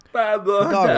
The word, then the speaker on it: Pablo Hidalgo: Pablo Hidalgo.